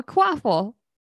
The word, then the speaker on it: Quaffle: It's a quaffle oh, a quaffle. Yes. Rhymes with waffle.